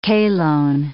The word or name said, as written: chalone